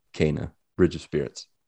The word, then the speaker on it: Kena: Correct me if I mispronounce, because I can't ever get this pronunciation right, but I think it's Kena, Bridge of Spirits.